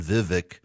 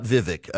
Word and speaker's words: Vivek: So his team said it's a hard E, so it's V, not V, like I've been saying, Vivek. It's not Vivek. It rhymes with cake.